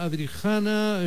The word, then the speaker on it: Adrijana: Bueno, Adriana, Adrijana, en fin, tu nombre es serbio, no sé si lo pronuncio bien, seguro que no. – Bien. Es en español es Adrijana, porque hay J entre medio, entonces sí que es Adrijana, pero bueno, está bien.